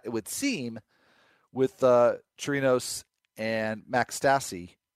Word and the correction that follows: Stassi: or Stacy. Not sure why I said that wrong initially, but uh, Chirinos and Stacy would seem to be the catching tandem in Houston.